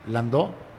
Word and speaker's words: landó: ¿Landó? Así, landoco, no, no, Christopher Landau es francés, ese es el apellido. El ritmo se llama landó, con acento en la O, L-A-N-D-O, y es afroperuano.